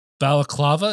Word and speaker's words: balaclava: If you're trying to get something printed, like Justin, let's say I'm like, hey man, I'm thinking of getting like a balaclava. Is that, did I say that right? – Yes.